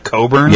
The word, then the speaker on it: Cockburn: I think you pronounced it Coburn. – Yeah, the CK was silent. – It was spelled cockburn.